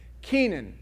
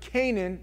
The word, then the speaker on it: Kenan: Kenan. Now, some people pronounce it Canaan. This is not Canaan. This is Kenan, which means sorrow, a dirge, or elegy, meaning a sorrowful cry, a weep.